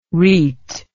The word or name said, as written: reit